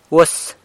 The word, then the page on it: uas: really